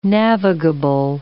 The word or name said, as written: navigable